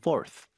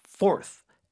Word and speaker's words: forth: Go back and forth. F-O-R-T-H 발음이요? – Forth. – Forth. – Forth. 네, 네 번째 그 forth하고 똑같아요.